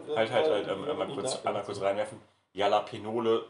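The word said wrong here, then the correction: Jalapeños: Jalapenose sind Jalapenos. – Ja, sie hat halt nur das Wort gelesen und hat es irgendwie halt falsch ausgesprochen, ne?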